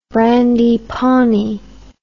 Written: brandy pawnee

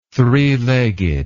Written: three-legged

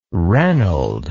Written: reynold

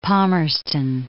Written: Palmerston

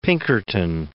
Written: Pinkerton

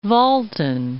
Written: Upper Voltan

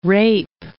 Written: rape

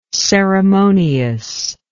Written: ceremoniousness

